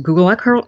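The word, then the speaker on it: Gullah: And I mean, this actually occurred, and this is just kind of a look at that culture, which they call the, I believe it's pronounced Gula culture. I've heard a couple different pronunciations. It depends on the accent, because I've heard the Creole used in this is different than the Creole that I've heard more.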